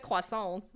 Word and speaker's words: croissants: She said croissants. – Uh-huh. She got it right. – And then it's like, has it spelled right? So I just put, no, baby, it's pronounced croissants.